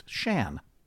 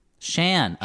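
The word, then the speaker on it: Siân: It is Welsh and is pronounced Shan. – Shan. – Oh, Shan all this Phillips. time. So now we know. Now we know. Okay, Shan.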